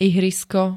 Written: ihrisko

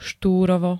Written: Štúrovo

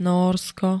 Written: Nórsko

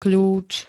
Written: kľúč

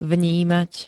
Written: vnímať

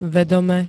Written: vedome

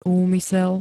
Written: úmysel

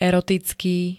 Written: erotický

